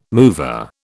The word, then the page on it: mover: British English